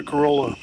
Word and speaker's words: Corolla: Is it Corolla or Corolla? – Corolla. And it was, that's what the locals say anyway, the northern part there of the Outer Banks.